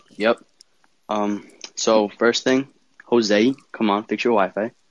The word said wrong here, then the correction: Josue: First off, it's Josue.